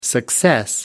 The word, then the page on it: success: American English